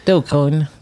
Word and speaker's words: Dogecoin: Uttalas det så? Dogecoin. Coin. – Mm.